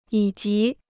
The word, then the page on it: 以及: yǐ jí